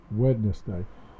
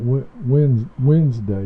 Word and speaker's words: Wednesday: And ever since then, I pronounce, you know, I try to Wednesday. I think I left the D out. Wednesday, you don't hear.